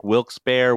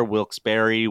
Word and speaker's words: Wilkes-Barre: But she says that Wilkes-Barre has several pronunciations. Everybody says it differently. Yes. It sounds like Wilkes-Barre, Wilkes-Barre, Wilkes-Barre, Wilkes-Barre.